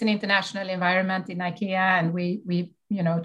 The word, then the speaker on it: IKEA: What is the correct way to pronounce IKEA, IKEA, or IKEA? Yes, um, uh, in Swedish uh, we say IKEA and uh, of course in english it's uh, more ikea